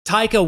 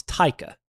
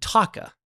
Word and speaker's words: Taika: What we didn't know was exactly how to pronounce Taika. I, in particular, didn't know how to pronounce it, and you didn't correct me. – Oops. – And so for the first half of the shoot of filming with them, I kept calling him Taka.